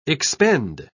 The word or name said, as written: expend